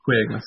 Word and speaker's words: Kwayga: Now, we've spelt it phonetically with K-W-A-Y-G-A to help our international non-Kwayga friends to, right. to say the word Kwayga.